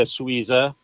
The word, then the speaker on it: Suiza: And so Sweeza was born, and the name Sweeza is just an easy way to say Super Quesadilla Sweeza, which is spelled S-U-I-Z-A, which is translated into Swiss.